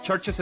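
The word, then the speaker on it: Chvrches: Sí, Churches, Churches es buenísima. Churches se escribe con, como con B de vaca, en vez de la U le ponen la B de vaca. Churches, así como iglesias, es buenísima banda.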